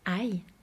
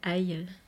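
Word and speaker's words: ai (爱): Moi, je vais juste terminer en te lisant le petit paragraphe sur l'amour, ai en chinois. C'est vrai que c'est un peu rigolo, aïe, l'amour qui fait mal. C'est comme ça que ça se prononce, ouais, en chinois.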